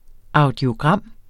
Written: audiogram

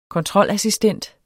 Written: kontrolassistent

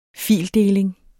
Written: fildeling

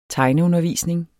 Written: tegneundervisning

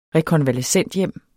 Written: rekonvalescenthjem

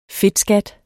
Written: fedtskat

